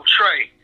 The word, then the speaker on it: Trea: He's like, we're going to spell it T-R-E-A. And I was like, man, that sounds like tree. He's like, nah, man. He's like, that's where you got to change it at, man.